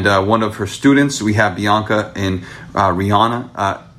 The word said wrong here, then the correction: Renaya: Is it Rihanna? Am I pronouncing your name right? – Renaya, my bad. Bianca and Renaya in the building.